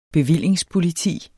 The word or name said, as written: bevillingspoliti